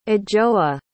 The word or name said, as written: adjoa